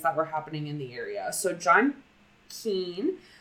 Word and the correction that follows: Keel: Keel. Sorry, it's John Keel. I've been saying it wrong this time.